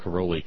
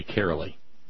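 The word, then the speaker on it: Carollo: I'm supposed to call Caroli Carolee. Says it rhymes with merrily, or so she told.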